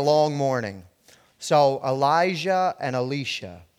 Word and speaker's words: Elisha: So Elisha the prophet's name is in Hebrew is pronounced Elisha.